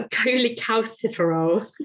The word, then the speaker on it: cholecalciferol: Oh, a cholecalciferol.